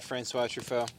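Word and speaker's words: François Truffaut: Okay, I might butcher it, but Francois Truffaut? – Yeah. – Oh, yeah, Francois Truffaut.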